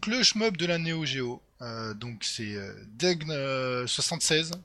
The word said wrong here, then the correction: DGEG76: J'ai toujours du mal à le prononcer, je suis désolé pour ton pseudo. DGEG. E voilà, DGEG76 qui nous avait fait ça.